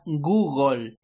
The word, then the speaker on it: Google: O también otra muy usada, Google, en realidad se pronuncia Google, como gol de fútbol, pues Google.